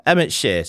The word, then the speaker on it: Emmett Shear: Anyway, so Emmett Shear. Have I said his name right? Yeah, Emmett Shear.